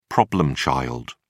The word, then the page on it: problem child: British English